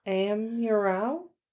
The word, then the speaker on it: Amurao: It's Corazon Amurao. Amurao? Yes.